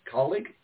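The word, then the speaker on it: Kaulig: Well, you know, certainly calling racing, how do you spell that? K-A-U-L, colleague? Did I say that right? Colleague racing? Yeah, okay.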